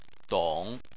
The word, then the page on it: dong: 3